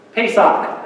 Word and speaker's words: Pesach: Pesach, that's how you pronounce it in Hebrew.